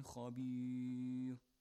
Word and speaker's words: خبير: When Ra is preceded, Ra, when it's preceded by kind of E sound, pronounce it lightly. مِن لَدُن حَكِيمٍ خَبِيرٍ. Not خَبِير. No. خَبِير. Lightly. No تَفْخِيم. Got it? It's as if it's carrying a كسر with it. Ri.